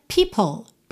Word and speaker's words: people: Nessas últimas duas linhas, people, atenção à pronúncia dessa palavra, né? Porque a escrita é P-E-O-P-L-E, P-O-P-L-E, como assim? A pronúncia é people, people.